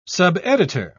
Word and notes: subeditor: British English

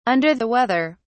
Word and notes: under the weather: British English

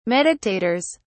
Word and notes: meditators: British English

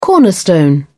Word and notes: cornerstone: British English

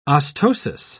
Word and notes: ostosis: British English

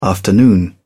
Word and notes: afternoon: British English

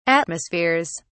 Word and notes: atmospheres: British English

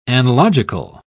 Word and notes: analogical: British English

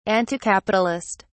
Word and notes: anticapitalist: British English

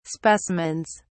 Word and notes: specimens: British English

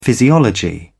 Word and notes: physiology: British English

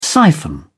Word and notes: siphon: British English

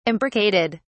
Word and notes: imbricated: British English

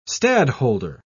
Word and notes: stadholder: British English

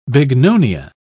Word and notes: bignonia: British English

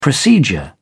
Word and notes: procedure: British English

